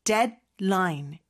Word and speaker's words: deadline: 네, 근데 발음은 deadline. 디 발음이 거의 먹는 듯이 사라지죠. 그래서 데드라인이라고는 하지 않습니다.